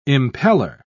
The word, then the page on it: impeller: British English